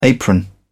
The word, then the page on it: apron: British English